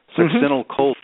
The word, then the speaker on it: succinylcholine: Now, let's talk about a very important drug, and I'll try to pronounce this properly, succinylcholine. Mm-hmm. And this drug, what is its characteristic? What's its main thing that it's known for? – Sure. And you did a pretty good job.